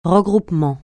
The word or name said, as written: regroupement